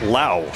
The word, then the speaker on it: Lowe: As Lane said earlier, about an hour and 20 minutes ago, his last name is not spelled the way you'd think. L-O-W-E, but pronounced Lau.